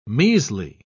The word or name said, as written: measly